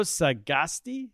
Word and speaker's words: Sagasti: Francisco Sagasti, is that right? – Yeah, that's perfect.